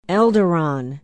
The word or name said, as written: elderon